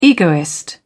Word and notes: egoist: British English

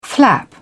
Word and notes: flap: British English

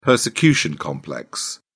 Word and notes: persecution complex: British English

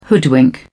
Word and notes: hoodwink: British English

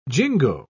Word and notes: jingo: British English

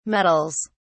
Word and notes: meddles: British English

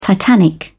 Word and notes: titanic: British English

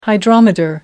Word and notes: hydrometer: American English

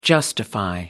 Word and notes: justify: American English

